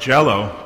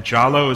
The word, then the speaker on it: Njalo: And it's, I said earlier, it's not Jello, but it's Jalo. And Jalo is spelled N-J-A-L-O. So we don't pronounce the N, we just say Jalo.